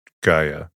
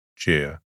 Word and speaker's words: Gaia: The Greek poetic name Gaia is rare, though the alternative spelling Gaia has become common due to the Gaia hypothesis, in which case its pronunciation is Gaia, rather than the more classical Gaia.